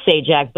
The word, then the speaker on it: Sajdak: He was known as Patrick Leonard Sajdak, pronounced Sajak, but there was a D in it.